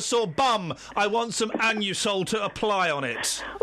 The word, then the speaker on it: Anusol: Listen, when I go in and buy my Anusol, that's how it's pronounced, I say it loud and proud.